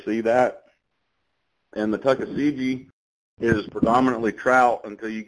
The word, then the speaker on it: Tuckasegee: Now, does the Davidson or the Tuckasegee, am I saying that right? – Tuckasegee, do those rivers have any of the warm water species, or are those strictly trout? – So the Davidson will.